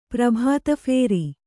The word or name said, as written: ಪ್ರಭಾತಫೇರಿ